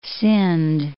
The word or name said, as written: Sindh